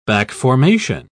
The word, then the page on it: back-formation: British English